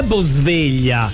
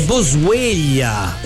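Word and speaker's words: Boswellia: Non è Bosveglia, è Bosveglia. – E cosa cambia? – Che si scrive diverso. Io pensavo alla sveglia, invece no, è Bo attaccato SWELLIA.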